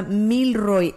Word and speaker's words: Milroy: Milroy, sí, como, bien, haga de cuenta como de mil, el número mil, y después Roy, R-O-Y, sí, pero es una sola palabra, Milroy, M-I-L-R-O-Y,